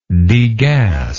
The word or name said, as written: degasser